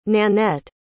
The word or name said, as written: nannette